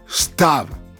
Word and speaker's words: CTAV: Si je vous dis stave Ok, ça s'écrit en réalité C-T-A-V comme c'est ta vie.